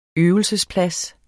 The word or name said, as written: øvelsesplads